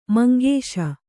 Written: ಮಂಗೇಶ

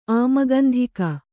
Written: ಆಮಗಂಧಿಕ

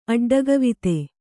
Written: ಅಡ್ಡಗವಿತೆ

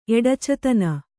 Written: ಎಡಚತನ

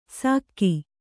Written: ಸಕ್ಕಿ

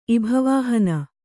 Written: ಇಭವಾಹನ

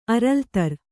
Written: ಅರಲ್ತರ್

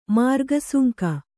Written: ಮಾರ್ಗಸುಂಕ

